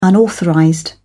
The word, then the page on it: unauthorized: British English